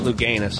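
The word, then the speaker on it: Louganis: Still my all-time favorite swimmer was that Greg Lucanus. That guy that hit his head. – Luganus. Remember him? Hit his head on what's his name? – Luganus. – Ah, I've been mispronouncing his name.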